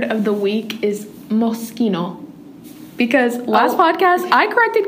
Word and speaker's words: Moschino: And I went home and looked it up and watched a pronunciation video, and it's moschino.